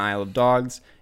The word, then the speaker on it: Isle of Dogs: Um, I mean, looking at Grand Budapest Hotel and Isle of Dogs, or Isle of Dogs, yeah, I said it correctly the first time.